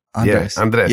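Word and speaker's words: Andrés: There you go. Andres. Yeah. Yeah. You say pretty good. You can roll your R.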